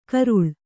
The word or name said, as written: ಕರಳಿ